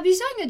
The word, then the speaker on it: bisogno: Bisogno, pronuncia bisogno, sempre com aquele som de NH, igual a gente viu lá no comecinho e tem aqui de novo o signora. Então, GN, som de NH.